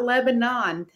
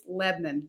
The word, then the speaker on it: Lebanon: It's not Lebanon, Tennessee. It's Lebanon.